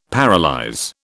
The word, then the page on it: paralyse: British English